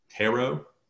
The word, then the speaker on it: tarot: Tarot? – Tarot. So it's spelled T-A-R-O-T, but the last T is silent.